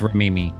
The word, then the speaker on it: Remini: Is it Remini or Rimini? I don't know. I feel like I never say it right. – I think right. – It's Rimini. Rimini.